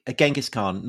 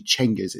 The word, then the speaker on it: Cengiz: So Genghis Khan, not the correct pronunciation, right? – It isn't actually. Well, as far as we can tell, because actually the more accurate pronunciation or rendition seems to be, and I dare say among Persian historians of the Mongol period, and it's actually Cengiz. It's a CH rather than a G.